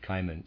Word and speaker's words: Cimon: Cimon, I'm going to pronounce that Cimon, C-I-M-O-N, a well-known historical figure, had a friend called Astyphilus, who knew how to interpret dreams.